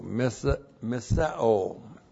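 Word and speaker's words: missa-o (μισέω): M-I-S-E long O, missa-o.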